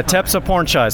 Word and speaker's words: Tepsipornchai: Tepsipornchai. So that last syllable, it's like chai tea, spelt and pronounced the same way.